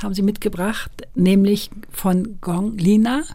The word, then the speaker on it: Gong Lina: Genau, Gong Lina, das ist sicherlich so das Chinesischste, was ich mitgebracht habe an Musik. Gong Lina hat eine Ausbildung gemacht zu einer klassischen Opernsängerin, also Peking-Oper.